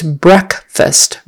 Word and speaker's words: breakfast: However, when we put it with the word fast and make it the first morning meal of the day, it's breakfast. Breck. Breck rhymes with trek. I can't think of another one. But short E.